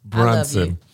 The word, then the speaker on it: Brunson: Is that, I said it wrong? Bronson. Listen. – Brunson. – Brunson does sound better than Braun.